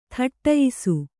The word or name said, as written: ಥಟ್ಟಯಿಸು